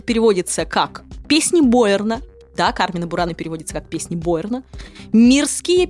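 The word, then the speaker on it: Carmina Burana: Так вот, правильно произносить следует «Кармина Бурана», а не «Кармина Бурана», как это делают сегодня очень многие.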